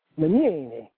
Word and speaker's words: Legnini: Well, if you're Italian, you know, like lasagna or signorita, the G and the N together, officially in Italian, make a Y sound. So the way you're supposed to pronounce the name is Legnini. It makes like a N.